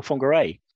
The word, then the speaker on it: Whangarei: And I had to spell it out for him, and he said, oh, Whangarei. And I was completely bemused by this, and I did some research, and I found out that the pronunciation is the New Zealand, or the Maori pronunciation of the word, rather than the English pronunciation.